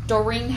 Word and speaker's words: Doreen: Doreen Howard, H-O-W-A-R-D. And my first name is spelled D-O-R-E-E-N.